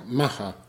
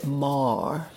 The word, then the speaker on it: Maher: No, some people say Mar, but that's the posh version of Gary. – See, a friend of mine who I come in with, her boyfriend used to be Mar, and she said it's pronounced Mar, and then her daughter said, no, it's Maher. – And the daughter's right.